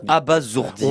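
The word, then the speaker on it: abasourdir: On dit bien abazourdir. On dit zourdir, c'est Z. – On dit D'ailleurs, c'est logique puisque le S est tout seul entre deux voyelles et dans ce cas-là, il se prononce Z.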